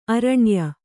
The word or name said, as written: ಆರುಣ್ಯ